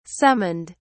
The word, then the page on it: summoned: British English